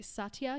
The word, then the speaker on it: Satya: She is a certified, and correct me if I'm saying this incorrectly, Satya Yoga? – That's it. – Yes. Satya Yoga.